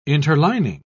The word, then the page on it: interlining: British English